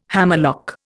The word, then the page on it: hammerlock: Indian